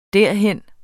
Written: derhen

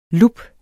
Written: lup

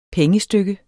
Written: pengestykke

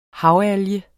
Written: havalge

